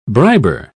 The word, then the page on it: briber: British English